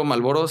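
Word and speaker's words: Marlboro: Que de hecho, Malboro, si ustedes no lo saben, se escribe con R antes de la L. Pero no, se pronuncia, pero no se pronuncia. Ajá. Es muda.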